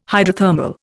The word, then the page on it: hydrothermal: Indian